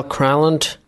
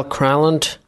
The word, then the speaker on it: Granlund: And let's see if we can hear how he actually pronounces his name. – Mikhail Granlund, center, Helsinki. – Crown? – Mikhail Granlund, center, Helsinki.